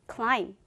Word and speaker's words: climb: 원래 B는 사운드가 B, B 사운드가 나지만 매우 특이하게 이 단어에서는 B 사운드가 나지 않아요. 그래서 마치 없는 것처럼 climb, climb으로 발음하시면 됩니다. climb가 아니라 climb, climb으로 하시면 돼요.